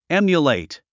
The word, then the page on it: emulate: émjulèit; エミュレェイト